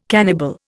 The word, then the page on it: cannibal: Indian